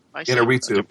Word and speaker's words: Iñárritu: Is that how you pronounce it? Iñárritu? – Iñárritu.